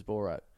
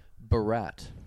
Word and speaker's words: Borat: This is how she pronounces Borat. Barat. – Barat. – Barat. – Wow. – Like he's French. – No, like he's Barat Obama.